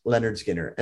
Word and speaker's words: Lynyrd Skynyrd: So Lynyrd Skynyrd, pronounced Lynyrd Skynyrd.